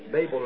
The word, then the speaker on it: Babel: it's just like it was at Babel again. Babel, rather. Now, we notice that God told Eve that after so long a time, the Word's coming back to you.